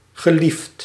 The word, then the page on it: populaire: France; [pɔ.py.lɛʁ]